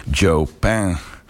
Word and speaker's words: Chopin: Frédéric Chopin uitgesproken als Chopin.